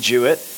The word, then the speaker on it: Juett: You can follow me at Andy Juett. That's at Andy Juett. It's just my name. My name is spelled J-U-E-T-T.